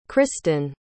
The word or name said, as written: kristyn